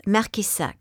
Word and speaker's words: Marqueyssac: It's Marqueyssac.